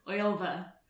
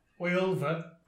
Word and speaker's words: Wylfa: Wylfa. – Wylfa.